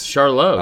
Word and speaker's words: Charlotte: Charlotte. We finally said it right, too.